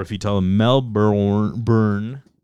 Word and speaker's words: Melbourne: So you sure it's Melbourne, not Melbourne? – No, Australians pronounce it Melbourne.